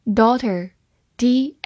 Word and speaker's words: daughter: daughter，d a u g h t e r，daughter，d a u 发 do，g h 不发音，t e r ter，daughter，daughter，它是一个名词，表示女儿。